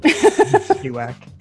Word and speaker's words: Spiewak: Spiewak.